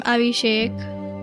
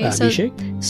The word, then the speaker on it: Abhishek: So they perform Abhishek that's how you say it, right? That is it. Yep. Okay, Abhishek.